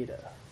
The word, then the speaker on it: Eta: It's a long E sound. A, as in obey. – Like it's our normal A. Eta.